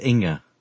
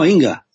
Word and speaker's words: Inge: Kirby, Lydia, looks like Ing or Inge. I'm sorry if I can't pronounce your name properly. – That would be pronounced Inga. – Oh, Inga. Fantastic.